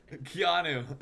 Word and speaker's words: Keanu: I don't know how to pronounce the guy out of Wisconsin's name, but it sounds like the actor. – Keanu Reeves. – Keanu. yes, incredible analysis.